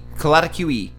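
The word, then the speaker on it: Coatlicue: Coatlic Q E. Sure. Yeah, you literally say every fucking letter in Aztec. You never skip them. There's no silent letters.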